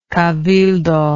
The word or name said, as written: cabildo